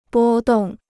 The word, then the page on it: 波动: bō dòng